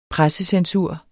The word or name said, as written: pressecensur